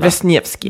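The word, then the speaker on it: Wrzesniewski: Och som jag förstår, Amy Brzev, nej, men det var jättesvårt att uttala. – Wisniewski, låter bra.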